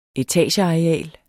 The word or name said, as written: etageareal